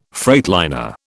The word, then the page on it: freightliner: British English